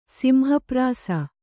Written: ಸಿಂಹಪ್ರಾಸ

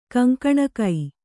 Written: ಕೊಂಕಣಿಗ

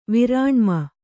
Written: ವಿರಾಣ್ಮನ

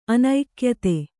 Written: ಅನೈಕ್ಯತೆ

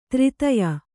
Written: ತೃತಿಯ

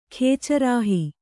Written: ಖೇಚರಾಹಿ

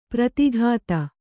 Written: ಪ್ರೋದ್ಗತ

